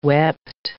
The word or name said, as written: wept